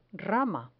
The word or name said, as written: rama